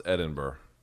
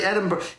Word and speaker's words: Edinburgh: How to pronounce Edinburgh.